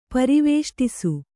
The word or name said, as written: ಪರಿವೇಷ್ಟಿಸು